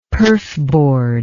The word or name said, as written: perfboard